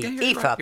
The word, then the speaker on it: Aoife: And that is from Aoife in Belfast. I think that's how you pronounce it. A-O-I-F-E. – Aoife. – It is. Aoife.